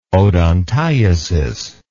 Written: odontiasis

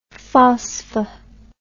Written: phosph

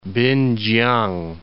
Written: Pinkiang

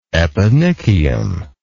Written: eponychium

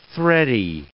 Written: thready

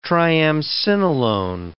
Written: triamcinolone

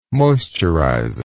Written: moisturizer

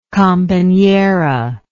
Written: companera

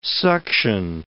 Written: suction

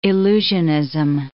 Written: illusionism

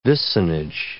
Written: vicinage

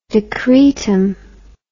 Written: decretum

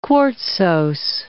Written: quartzose

